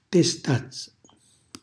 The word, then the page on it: testazza: Calabrese